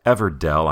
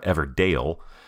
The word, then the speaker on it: Everdell: Again, the name is Everdell. I'm not mispronouncing it. It's not Everdale. E-V-E-R-D-E-L-L, like Farmer in the Dell.